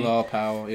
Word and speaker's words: Bilal Powell: Bilal Powell, yeah.